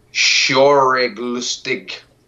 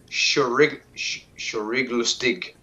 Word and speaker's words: Schauriglustig: Schauriglustig. Now, let me spell it for you so you know I'm actually saying it the way this shit is spelled. S-C-H-A-U-R-I-G-L-U-S-T-I-G.